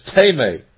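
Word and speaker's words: timē: It's spelled in the Greek, T-I-M-E, like time. You want to pronounce it time, but it's teme.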